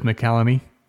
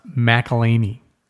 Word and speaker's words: McElhaney: I said it was McCallany. And he said it's pronounced McElhaney, which sounds a little bit like some sort of Christmas cookie.